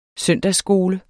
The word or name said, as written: søndagsskole